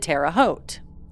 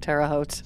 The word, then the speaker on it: Terre Haute: Terre Haute. God, I hope it's pronounced Terre Haute.